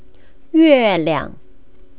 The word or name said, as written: yueliang